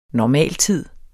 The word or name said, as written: normaltid